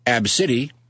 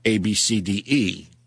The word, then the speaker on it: Abcde: Because the daughter's name is Ab City. – Right. – But it's spelled ABCDE. – Yeah, that's not stupid. – I thought it was Ab CD.